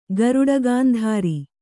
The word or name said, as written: ಗರುಡಗಾಂಧಾರಿ